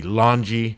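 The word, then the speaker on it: Laulauga: It's like LAWN-GE, Lanji, and then last name is Tusaga.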